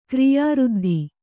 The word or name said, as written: ಕ್ರಿಯಾಋದ್ಧಿ